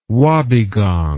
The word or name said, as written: wobbegong